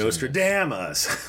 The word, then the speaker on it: Nostradamus: Nostradamus.